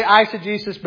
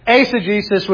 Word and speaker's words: eisegesis: A lot of people say eisegesis, but it's actually eisegesis, which means to read into something.